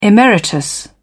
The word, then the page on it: emeritus: British English